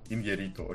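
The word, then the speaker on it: Iñárritu: Вот он идет два с половиной часа, я такой подумал, ну, Иньяриту, окей, Иньяриту хорошо снимает. – Кстати, да, я, я в прошлый раз э, провинился, конечно, Иньяриту. Там ударение у него на «я», э, извините.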